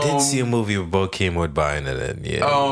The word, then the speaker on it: Bokeem Woodbine: There was fundamental flaws, you know, and that's the crazy part, is that there were so many good performances, like Hakeem Woodbine. – Bokeem Woodbine.